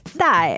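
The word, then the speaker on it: dai (戴): To say wear a surgical mask or a mask is called dai, which is dai is to wear it on your face.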